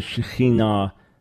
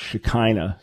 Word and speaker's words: Shekinah: Yeah, so they would have been referring to the Shekinah, which many Christians pronounce Shekinah, so the presence of God, the glorious presence of God, the presence of God's glory.